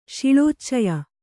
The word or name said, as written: ಶಿಳೋಚ್ಚಯ